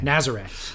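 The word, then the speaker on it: Nazaré: I was all proud of myself that I put the accent on the last syllable. – Dude, I gave Nazare. – You the... No, you... I phonetically Nazare. Spelled it out for you. And then you phonetically spelled it wrong somehow. It's Naz. – Naz? – Naz. Ure. – Nazare.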